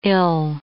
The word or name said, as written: ill